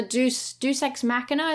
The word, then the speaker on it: deus ex machina: And there's a deus ex machina, is that how you say it? – Deus ex machina. – Deus ex machina, there we are, at the end, and everything sort of all goes back to normal.